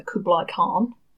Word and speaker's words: Kublai Khan: Under the power that is granted to him by the Kublai Khan.